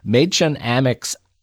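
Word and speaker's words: Mädchen Amick: But I also, by the way, on this film, want to draw viewers' attention to Maychen Amick's eyebrows. I hope I'm pronouncing her name right. – Yes.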